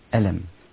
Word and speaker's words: ألم: فالنطق في فواتح السور على أصل نطق الحرف ألف لام ميم، ولا تستطيع أن تقرأ ألم، إنما هنا في هذه الآية تقول ألف لام ميم، كلا، بل تقول ألم.